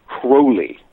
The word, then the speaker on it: Crowley: What evidence did you find to support that Crowley, is it Crowley or Crowley? – Crowley. – Okay, because I've always pronounced it Crowley, but anyway.